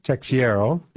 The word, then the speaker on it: Teixeira: Okay, tell us about this story about this Joseph, how do you say his name? – Well, it's Jerry Teixeira. – Yeah. – It's a Portuguese name,